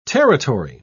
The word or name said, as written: territory